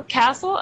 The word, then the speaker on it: castle: The T is right? – always silent, isn't it? – Castle.